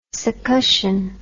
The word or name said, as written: succussion